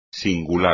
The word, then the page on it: singular: espagnol